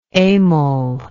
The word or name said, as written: emil